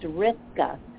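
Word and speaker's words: Roethke: And kind poet was kind enough to point out, and I did not realize this, so I thank her, that that poet's last name is actually pronounced Rizka. So it's Theodore Rizka.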